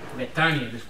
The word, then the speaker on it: Betania: Betania, disculpa. – Mal pronunciación gringa. Ok, Betania. Betania, ¿qué dices? – Betania.